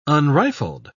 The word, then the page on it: unrifled: British English